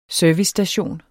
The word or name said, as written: servicestation